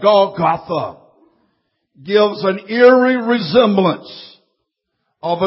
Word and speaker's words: Golgotha: In our English, it's pronounced Golgotha.